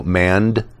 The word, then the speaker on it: Mandeight: The books are, the first book is Mandite and the Apprentice Mage, M-A-N-D-E-I-G-H-T, so Mandite, but it's the German pronunciation. So it's Mandite.